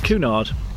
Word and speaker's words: Cunard: Hang on, how did you pronounce that? – Cunard. – Cunard. – Both are correct. Tomato, tomato.